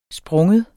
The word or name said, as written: springe